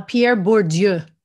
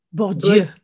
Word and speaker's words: Bourdieu: bordeaux Bourdieu. Yeah. Bur- Bur- wait, how do you say it? – Uh, Pierre Bourdieu. – Bourdieu. Bourdieu. Okay.